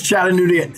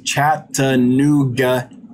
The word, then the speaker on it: Chattanooga: Thanks, Chattanooga. I butchered it. Thanks, Chattanooga Ed, for the $5 super chat.